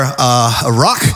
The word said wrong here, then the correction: ruach: uh, rock, I don't, I'm massacring that pronunciation. Uh, languages is not my specialty. And so in the New Testament, the word for spirit is pneuma. Old Testament, the word is ruach.